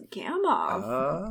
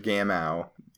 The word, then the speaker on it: Gamow: I always thought his name was Gamow. Yeah, I think he's from some country where the W is pronounced with a V.